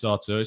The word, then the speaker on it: Arthur: JDB says, why don't you pronounce Arthur Mello like you pronounce Arthur Okonkwo? You sound like Shakespeare. I think it's Brazilian. I think it's Arthur. I have it on pretty good authority. It's Arthur. It's not Arthur.